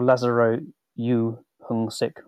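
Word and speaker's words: Lazaro You Heung-sik: He was, until the Pope's death, the head of the Dicastery for Clergy, Cardinal Lazaro Suk. Sorry, Dame, I'm going to have to just double check the pronunciation of his name because forgive me here. Cardinal Lazaro Yu Hung Sik, or Cardinal Yu.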